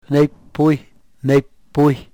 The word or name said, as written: nepuy